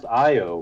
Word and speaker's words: Io: members of EO with me. That means you can talk, guy. – It's actually pronounced I-O, Mike.